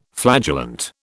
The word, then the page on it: flagellant: British English